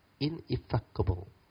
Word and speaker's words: ineffable: I will tell you, a totality of mark is ineffable. How to pronounce it? Ineffable.